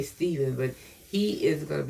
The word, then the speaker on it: Stephen: Now notice Pastor said Stephen, and that's the way it's pronounced. Glory to God, Stephen. But many people say Stephen. But it's S-T-E-P, okay? So it's Stephen.